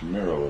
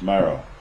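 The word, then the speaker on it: Mirow: Yes, He flew here for Hans Miro in the first place. – Who? – Hans Miro. – Hans Miro? – Yes, M-I-R-O-W, I believe is the way he pronounced it.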